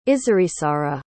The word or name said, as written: Izarizarra